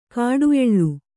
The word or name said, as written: ಕಾಡುಎಳ್ಳು